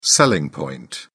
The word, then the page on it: selling point: British English